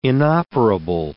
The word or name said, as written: inoperable